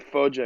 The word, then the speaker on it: Foggia: Is that how you pronounce it? – Yeah, so the province is called Foggia, exactly. And then I didn't even include it when I was kind of like giving you the description.